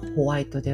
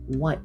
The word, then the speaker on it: white: 英語の発音はホワイトではなくて white なので、えー、 white shirt が Y シャツというふうに見えたか、あの、聞こえたかもしれません。